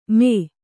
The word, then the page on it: ಮೇ: Kannada